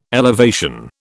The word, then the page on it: elevation: British English